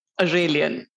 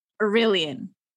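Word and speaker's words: Arelion: So let's talk about how Telia Carrier is now Aurelion. – Aurelion.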